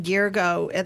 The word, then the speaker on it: Girgho: And the pronunciation, Girgo, that's not how it's spelled.